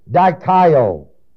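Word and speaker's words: dikaio (δικαιόω): That's why the word justified is the word dikaio. I've explained that word already in the book of Romans to you. It's spelled D-I-K-A-I-O-O. Last O is long.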